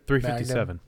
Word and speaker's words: 357 Magnum: How do you say this? 0.357? – 357. – Magnum. 357 Magnum. – Yep, 357 Magnum.